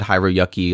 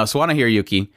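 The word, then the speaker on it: Sawano Hiroyuki: I would totally probably said Hiro Yuki a long time ago, back when I used to not pronounce things well. No, Suwana Hiroyuki.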